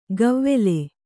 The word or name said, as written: ಗವ್ವೆಲೆ